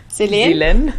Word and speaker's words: Selen: Und mein super Top-Tipp für Seelen oder wie wird das ausgesprochen? – Selen? Für die Seelen.